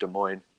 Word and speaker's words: Des Moines: Some people might think it's pronounced like Des Moines or something, but no, it's pronounced Des Moines.